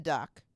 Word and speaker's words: Duc: Oh, it's spelled capital D-U-C. So duck without the K.